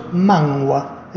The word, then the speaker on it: manhwa: mangua, ¿eh? los manguas. ¿Qué es un mangua? Bien, vamos primero a decirles cómo se escribe correctamente. Un mangua se escribe M-A-N-H-W-A.